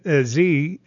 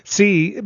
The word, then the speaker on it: Xi: And so now to be standing on a stage with President Xi, I'm sorry, I mispronounced that, Xi, but you're sitting there and you don't know how they're going to react to this.